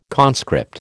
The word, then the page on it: conscript: American English